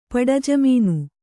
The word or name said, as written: ಪಡಜಮೀನು